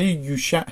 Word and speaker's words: یوشع: کلمه عبری هست و یعنی نجات دهنده. تلفظ عبریش همون کلمه یوشع هست.